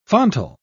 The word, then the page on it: fontal: British English